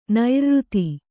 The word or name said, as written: ನೈರ್ಋತ